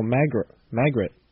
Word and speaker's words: Magritte: That was The Secret Margaret, spelled M-A-G... Oh, no, Magritte. Magritte? M-A-G-R-I-T-T-E. Pronounce it yourself. – Magritte. Maybe? – The Secret Magritte. Okay, by Larry Ox. O-C-H-S.